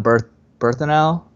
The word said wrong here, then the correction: Bernthal: No, so it's John, John Bernthal. Bernthal.